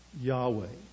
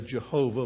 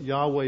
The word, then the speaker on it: Yahweh: And whenever you see that in the English Bible, in the Old Testament, what that means is this is the very name of God, Yahweh. We sometimes say Jehovah, but Yahweh is probably closer to how the original would be pronounced.